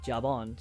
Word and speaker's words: Jabon: Picard's back at the vineyard, and he's getting ready to go, and he's saying his goodbyes to Laris and Jabon. So I mispronounced it last episode. It's not Zabon, it's Jabon, which is a very French-sounding name.